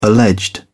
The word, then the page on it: alleged: British English